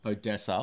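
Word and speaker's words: ODESZA: Anyway, it's got Zach Bryan, Lana Del Rey, Odyssey? Is that how you say it? – Odessa. – Odessa. Fuck. – Oh my God. Where is the Y in that? – Anyway, change. – No. It's Odessa. Z-A.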